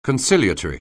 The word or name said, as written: conciliatory